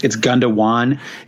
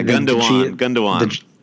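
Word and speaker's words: Gündoğan: I'm definitely mispronouncing his name, by the way, too. It's Gundogan. – Yeah. Gundogan. – The G is pronounced like a W.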